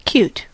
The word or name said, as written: cute